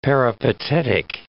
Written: peripatetic

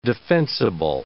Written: defensible

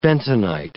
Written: bentonite

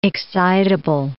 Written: excitable

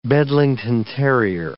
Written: Bedlington terrier

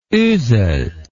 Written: Oesel

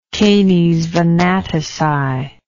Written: canes venatici

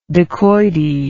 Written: dakoity

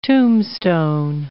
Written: tombstone

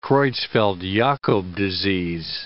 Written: Creutzfeld-Jakob disease

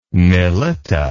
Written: Mylitta

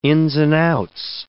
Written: ins and outs

